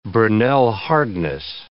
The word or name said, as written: Brinell hardness